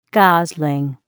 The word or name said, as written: gosling